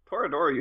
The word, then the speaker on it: Toradora: They made one of multiple for Toradora, and I've never pronounced that one right either, Orimo. – Toradora you had right.